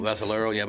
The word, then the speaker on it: Vassalero: I mean, Vassalero, but I grew up with Italians and Sicilians, so that's why. – It's only three syllables. Vassalero. – Four syllables.